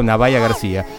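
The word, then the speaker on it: Nubya Garcia: Eh, largamos con una saxofonista británica que está a full ahora, que es Navaya García, real Nubia se escribe, pero se pronuncia, eh, me he enterado, Navaya García.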